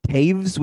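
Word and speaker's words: Toews: Devon Taze? – I don't know. – Taves with a V pronunciation.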